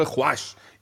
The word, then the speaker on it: خوش: خوش این واو واو معدول است خانم شهرزاد انگاری که تلفظ نمیشه که اصلا در مثلا واژه خواهر رو امروز ما مینویسیم واو دیگه نمیخوانیم